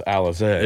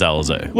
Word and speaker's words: Alizé: According to every rap song in the 90s, yes, it's Alizé. – It is Alizé.